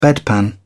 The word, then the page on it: bedpan: British English